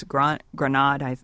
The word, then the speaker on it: Granade: District Court Judge Kelly V.S. Granade, I believe is how she pronounces her name, declared Alabama's ban on same-sex marriage unconstitutional under federal law.